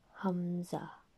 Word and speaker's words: Hamza: Similarly, moving on to another throat letter, which is also pronounced at the bottom part of the throat, is called the letter Hamza.